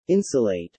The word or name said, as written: insulate